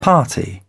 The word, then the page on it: party: British English